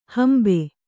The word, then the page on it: ಹಂಬೆ: Kannada